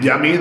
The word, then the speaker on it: Yumminn: A ver, la realidad es que se dice yamin. Ajá. Es la forma de yamin, la palabra de yamin está bueno.